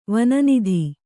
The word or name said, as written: ವನನಿಧಿ